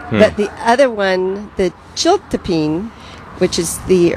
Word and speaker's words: chiltepín: Yeah, it's pronounced Chiltepín, but it's C-H-I-L-T-E-P-I-N.